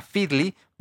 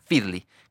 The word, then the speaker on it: Feedly: Tú te bajas esta aplicación que se llama Feedly, ¿vale? Eh, se escribe F2S D-L-Y, Feedly, como feed, pero con una L y una Y al final, todo junto.